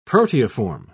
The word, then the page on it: proteiform: British English